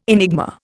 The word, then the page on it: enigma: Indian